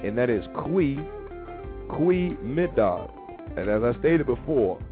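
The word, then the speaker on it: goedemiddag: So like your G, your G is actually a C, is actually a Q. It's also like Q. You don't say G. You don't look and say Gwee Mid Dog.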